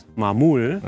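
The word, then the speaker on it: Maamoul: It's M for Madrid, A-A, another M for Madrid, O-U-L. – And it's pronounced? – Mamul.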